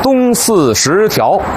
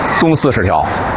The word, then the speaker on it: 东四十条: 还有今天呢咱们来到的这个地方东四十条，这是标准发音啊，但是您得琢磨琢磨这怎么断句呀。您肯定听过有人念成东四十条，其实啊应该念成东四十条，因为过去北京城啊从这个东四往北有 14条东西走向的胡同，就是东四头条到东四 14条。